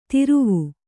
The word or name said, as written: ತಿರುವು